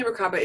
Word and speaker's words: Merkaba: Merkaba. – Yeah. And learning the Merkaba is super easy.